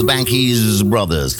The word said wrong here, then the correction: Banks Brothers: The Banks Brothers, so spricht man es eigentlich richtig aus.